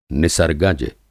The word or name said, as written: निसर्गज